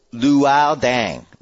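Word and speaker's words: Luol Deng: It is pronounced Luol Dang, like Pooball. Luol. Pooball.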